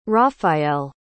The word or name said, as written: raphaëlle